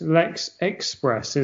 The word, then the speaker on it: Express: Although I'm pretty sure it's pronounced Lex Express as well, not Espress, as I just said.